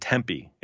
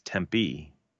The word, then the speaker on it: Tempe: You said Tempe. It's Tempe. – Oh my gosh, really? Tempe. – Yeah, they're particular here. I learned. – They're particular quickly. There. – They are particular. You have to put the right emphasis on the right syllable.